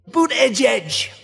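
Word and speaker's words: Buttigieg: All right, so here's the official pronunciation. – Boot edge edge.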